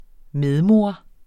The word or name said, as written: medmor